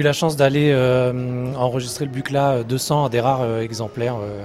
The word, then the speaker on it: Buchla: c'est Donald Buchla, ou Buchla, enfin ça se prononce Buchla,